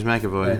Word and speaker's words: McAvoy: McAvoy? – Yeah, James McAvoy.